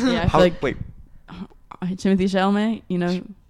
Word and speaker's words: Chalamet: That's how you pronounce his last name? – Yeah. – How did you think it was? – I don't know. I thought it was, it's Chalamet. I thought it was. – Chalamet. – I thought it was like, let me see how it's spelled.